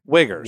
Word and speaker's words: Uyghur: Uyghurs. – What? – Those are the Muslims, the Chinese Muslims. Right. That's what they're called. – Oh, that was a name for a... – No, U-Y-G-H-U-R-S. It's pronounced Uyghur.